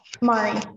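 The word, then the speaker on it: Mairi: Mari, yes.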